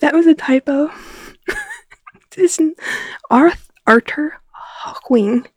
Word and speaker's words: Hawkwing: It is Arthur Hawkwing. – Okay, Hawkwing. I always call him Hawking, and that's not correct.